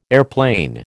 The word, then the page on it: airplane: American English